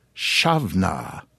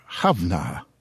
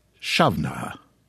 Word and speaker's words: Samhain: According to those who have studied the matter, or who just know such things, the barely pronounceable Samhain should be pronounced Shavna, if you're a male, or Havna, if you're a female. However, as I understand it, nowadays most Gaelic-speaking folk, men or women, simply pronounce it Shavna.